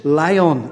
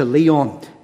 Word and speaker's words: Lyon: I think it's Lyon as opposed to Leon, so in the pronunciation.